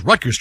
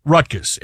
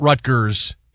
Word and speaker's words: Rutgers: And people are on social media right now going, why is Rutgers trending? – Yeah. – And just so everybody know, it's Rutgers. It's not Rutgers. It's Rutgers. That's how you say it.